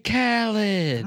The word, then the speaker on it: Khaled: Yeah, DJ Khaled, did I say that right? Why are you looking at me like that? – DJ Khaled. – DJ Khaled. – Right.